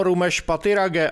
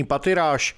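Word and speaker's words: Rumesh Pathirage: Vyslovoval své jméno Rumeš Patirage a vyloučil anglickou výslovnost svého příjmení Patiráš.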